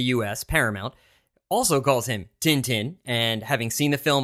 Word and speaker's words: Tintin: But here in our trailers and the audio for the film version here, they do call him Tintin, which is the way one might pronounce his name if you just glance at the word.